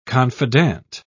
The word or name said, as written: confidant